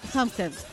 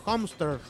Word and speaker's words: Homestead: Hampstead. – ¿Hamster? – Homestead. – Homestead. Home Center Sodimac. No. – Home. Es como Home Center y Hamster.